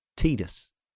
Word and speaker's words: Tidus: According to the developers, the former is the correct way. So it's officially Tidus.